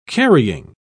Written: carrying

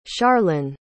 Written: charlynn

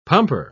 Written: pumper

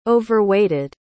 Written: overweighted

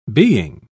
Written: being

